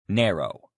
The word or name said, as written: narrow